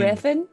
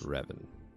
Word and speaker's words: Revan: I would like to go to Ravain. Did I say it wrong? Fuck me. – You mean Revan? Oh, motherfucker. I'd like to go to Revan.